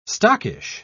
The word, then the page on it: stockish: British English